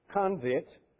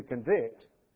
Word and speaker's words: convict: In English, we have this odd way of changing a noun and a verb just by, as one fellow said, putting the emphasis on a different syllable. And we can change convict to convict and make it a verb or a noun.